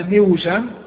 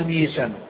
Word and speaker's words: ميسم: تصير أصلها ميوسم ميوسم فسكنت الواو وبعد كسر فثقل النطق بها فقلبت إلى ياء فقيل لها ميسم بدل ميوسم.